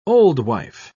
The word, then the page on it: oldwife: British English